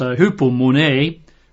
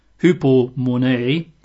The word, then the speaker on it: hupomone: I'll spell it as usual, but it's hupomone, which is H-U-P-O, as in hupo, and then M-O-N-E, a bit like money, hupomone.